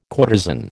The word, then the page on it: courtesan: American English